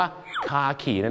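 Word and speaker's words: khaki: แต่ภาษาอังกฤษก็จะออกเสียงว่าคาขีนั่นเองครับใช่ครับคล้ายๆกับคาขีนั่นแหละ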